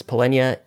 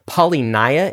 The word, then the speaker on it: Polynya: Polynya is a crypto anon. David and I have called this individual Polynya in the past. Turns out that is not how you pronounce Polynya's name. It's Polynya. We did this conversation in kind of a unique way.